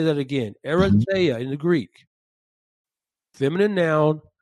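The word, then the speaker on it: erythea (ἐριθεία): Now, the phrase selfish ambition in James chapter 3, verse 14 is the Greek word erythea. Erythea. Erythea is spelled E-R-I-T-H-E-I-A.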